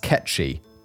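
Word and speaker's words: cat sìth: Oh, apparently it was pronounced ketchy. Cat cider is how it's spelled, but apparently it's pronounced Ketchy because Gaelic.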